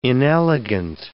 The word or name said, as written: inelegant